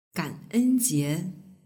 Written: ganenjie

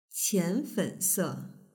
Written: qianfense

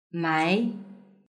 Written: mai

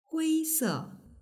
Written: huise

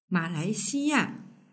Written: malaixiya

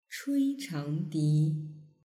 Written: chuichangdi

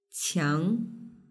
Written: qiang

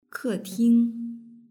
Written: keting

